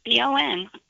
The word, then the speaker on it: Audubon: Audubon. Across my heart, I'm pronouncing it correctly.